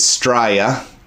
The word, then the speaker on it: Straya: The correct pronunciation of Australia is Straya, spelled S-T-R-A-Y-A. Straya, mate.